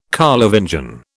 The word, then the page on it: Carlovingian: British English